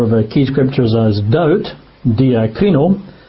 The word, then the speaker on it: diakrino: And the Greek word is diakrino. I'll spell that if you're taking notes. It's spelt D for donkey, I-A-K-R-I-N-O, diakrino.